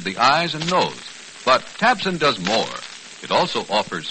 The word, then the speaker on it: Tabcin: So now try Tabsin. That's spelled T-A-B-C-I-N.